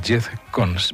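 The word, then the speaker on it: Giethoorn: Un sitio encantador, Jetcon, más o menos se pronuncia así, se escribe Jetcon y está en los Países Bajos.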